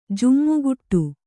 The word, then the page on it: ಜಿಮಿಗುಡು: jimiguḍu